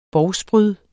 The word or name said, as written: bovspryd